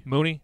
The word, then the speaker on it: Mooney: And then Mooney, is that how you pronounce it? – Mooney, thank you.